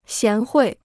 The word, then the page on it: 贤惠: xián huì